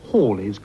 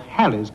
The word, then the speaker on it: Halley: If you mean the comet that is about to reappear in 1986, the correct pronunciation is Halley's Comet and not Halley's Comet,